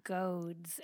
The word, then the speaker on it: goads: But prior to surrender, it's the idea of kicking against the goads, and that's spelled G-O-A-D-S, not goats like the animal, but goads.